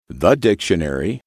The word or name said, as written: the dictionary